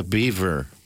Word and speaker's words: Biewer: The Beaver. – Uh huh. It's not spelled beaver. It's spelled B I E W E R, but it's pronounced like beaver.